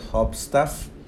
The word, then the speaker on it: Hubstaff: Y bueno, para aprovechar esta oferta de por vida puedes entrar a enlac.ee e diagonal hopstaff hopstaff se escribe H-U-B-S-T-A-F-F.